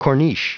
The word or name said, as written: corniche